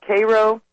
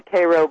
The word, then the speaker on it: Cairo: And the Army Corps of Engineers blew up the levee to save a little town called Cairo. Everyone here calls it Cairo, but it's spelled Cairo, like Cairo, Egypt.